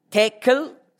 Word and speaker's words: tekel: Mene, mene, tekel, die erste Silbe betont.